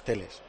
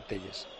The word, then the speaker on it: Telles: No, y él dice además, ha dicho aquí que como se pronuncia es Teles, que no Telles.